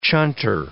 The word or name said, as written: chunter